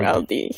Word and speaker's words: MRLD: So, hindi talaga Emerald ang pag-pronounce nito, no? Kala ko talaga Emerald. – MRLD.